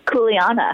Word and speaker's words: Kuleana: It's called, I'm going to butcher the name, apologies, mm-hmm. Kulina? – Kuleana. – That's pretty good.